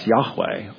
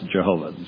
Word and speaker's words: Yahweh: Yahweh or Jehovah as we slaughter it in English. Could never have been pronounced Jehovah. Jehovah has too many syllables in it.